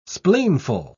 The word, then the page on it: spleenful: British English